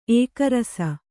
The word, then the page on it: ಏಕರಸ: kannada